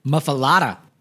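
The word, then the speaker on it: Muffuletta: Muffaletta. – Yeah. I know I never can pronounce that right. Thank you, Dan from Louisiana. – You said it right.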